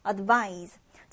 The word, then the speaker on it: advise: 그런데 발음 같은 경우에는 이제 동사는 Z 발음해줍니다. Advise, advise 이렇게 조금 더 무거운 발음이 나고요. 한편 명사는 S 발음을 합니다. 그래서 조언이라고 할 땐 조금 더 가볍게 advice 이렇게 발음하면 됩니다.